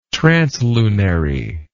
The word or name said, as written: translunary